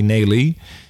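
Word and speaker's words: Neelie: Like in nail her, only Nelly.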